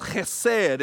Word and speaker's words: chesed: It's pronounced chesed.